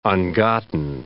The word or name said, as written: ungotten